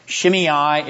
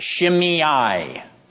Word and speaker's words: Shimei: Here we have Ziba, Shimei. It's pronounced Shimei.